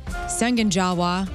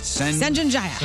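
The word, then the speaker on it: Sangenjaya: Roomie wanted, man for woman, 29 years old, Sengenjawa, Japan. – You totally butchered that. – I did. How do you say it? – Sengenjaya.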